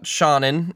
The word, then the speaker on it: Seanan: Well, I can talk a bit about Seanan McGuire, and that's how you pronounce the name, Seanan, not Seanan, Seanan.